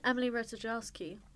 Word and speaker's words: Emily Ratajkowski: And even Emily Ratajkowski, I hope I'm saying that right.